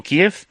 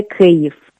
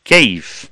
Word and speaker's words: Kyiv: Entonces, dime cómo tengo que decir Kiev adecuadamente, porque al parecer, si digo Kiev, estoy siendo prorruso. – Porque pronunciar Kiev es pronunciar en ruso. En ucraniano lo correcto sería Kyiv. – Kyiv.